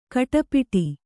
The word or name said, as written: ಖಟಿಪಿಟಿ